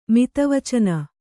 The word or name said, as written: ಮಿತವಚನ